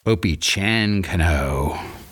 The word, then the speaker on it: Opechancanough: So here are the ways in which modern Virginians pronounce the names of the main protagonists in the book: Powhatan, Pocahontas, Opechancanough."